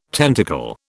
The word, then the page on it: tentacle: British English